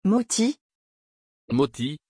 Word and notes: Motis: French

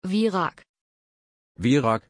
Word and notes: virág: German

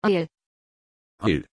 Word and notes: énael: German

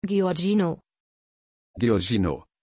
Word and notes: Giorgino: German

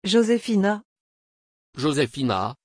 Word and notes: jozefina: French

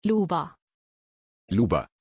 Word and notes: luba: German

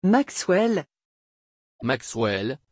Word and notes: Maxwell: French

